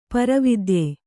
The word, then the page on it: ಪರಾವಿದ್ಯೆ: kannada